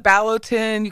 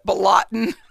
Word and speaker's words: Ballotin: You could call it Ballotin. You could call it Balotin.